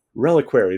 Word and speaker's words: reliquary: I can never say this term correct, but the reliquary? Reliquary, there we go.